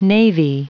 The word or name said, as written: navy